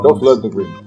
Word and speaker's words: Dolph Lundgren: Dolph Lundgren.